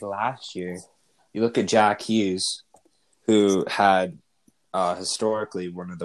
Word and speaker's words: Jack Hughes: I did it. I said it right. – Yeah. – Jack Hughes.